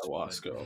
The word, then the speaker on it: ayahuasca: Oh, ayahuasca. I'm sorry. I mispronounced it.